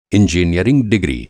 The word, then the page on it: इंजीनियरिंग डिग्री: Hindi